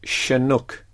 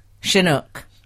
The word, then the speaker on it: Chinook: So how do we say it then? – It should be Chinook. – Chinook.